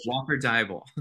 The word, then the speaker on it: Walker Deibel: Walker Deibel.